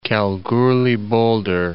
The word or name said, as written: Kalgoorlie-Boulder